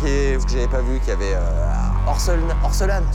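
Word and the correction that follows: Orelsan: C'est Aurel San. – Orselane, putain, moi j'aime pas trop ce que tu fais. – Ouais. – Ça okay. Se prononce Aurel San, San, San, San, San, San, San, San, San, San, San, San, San.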